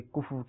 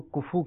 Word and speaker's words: کفو: نکاح میں ایک کفو کا لفظ استعمال کیا جاتا ہے کہ کفو کے ساتھ نکاح ہونا چاہیے. عام طور پر لوگ اس کو کفو کہتے ہیں, اس کا درست تلفظ کفو غالباً. – فا کے اوپر بھی جزم ہے اور واو کے اوپر بھی جزم ہے.